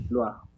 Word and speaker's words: Levallois: A ver, aquí está Al Levalois. No ¿Cómo? Levalois Que se escribe Levallois. Pero como yo sí sé hablar francés, Levalois